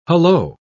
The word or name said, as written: hullo